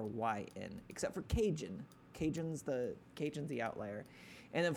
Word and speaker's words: Kajun: It's Cajun? K-A-J-U-N. Cajun.